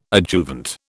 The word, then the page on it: adjuvant: British English